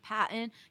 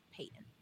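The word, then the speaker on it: Paton: And actually, for anyone listening, that is how you pronounce it. I know a lot of people have thought it's Patton because it's P-A-T-O-N, or there's a bunch of different pronunciations, but it is George Payton.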